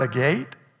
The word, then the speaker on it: agate: The first foundation was jasper, the second sapphire, the third agate, the fourth, emerald. Is that agate or agate? Agate. Is that the southern pronunciation or the northern? Or the western? Is that the western? That's western, agate.